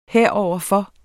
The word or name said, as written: heroverfor